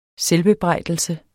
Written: selvbebrejdelse

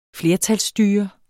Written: flertalsstyre

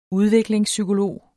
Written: udviklingspsykolog

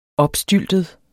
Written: opstyltet

